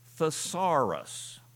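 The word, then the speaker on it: thesaurus (θησαυρός): The Greek word sounds like this, thesaurus. Now, what English word do you suppose we get from that Greek word? Thesaurus.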